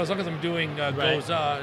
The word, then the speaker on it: Gose: But really the idea was people often mispronounce the style Goza. – Right. – It's G-O-S-E. People say Goes, Gose. Mm-hmm. You know, they often confuse it with a different style, which is a Goose.